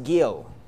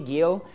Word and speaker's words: Gill: And Raji's last name is pronounced Gill, right? Because his father is of Indian descent, so it's a hard G, Gill.